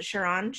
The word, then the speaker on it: Chiron: Chiron.